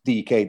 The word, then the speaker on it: Dike: Daryl DK, it's spelled Dyke, but it's DK, that's how he wants it pronouncing, who broke into the USA squad.